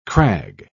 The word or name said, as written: crag